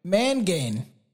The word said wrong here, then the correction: Monaghan: Where are we? – Monaghan. – Oh, Monaghan. – Monaghan. Okay, so you do the Okay, that's the super soft G.